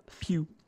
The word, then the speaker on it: Pugh: Well, one of the main characters, played by Florence Pugh? Pugh. I don't know how to pronounce that. – Pugh. – Pugh. Florence Pugh.